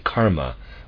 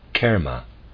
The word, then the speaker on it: karma: people very often say karma with broad ah sound. This is incorrect. It should be pronounced kerma with modified vowel sound.